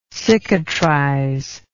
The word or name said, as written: cicatrizer